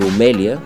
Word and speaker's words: Румелия: Сменя се ударението и се добавя едно «я», но иначе Румели и Румелия са си в употреба.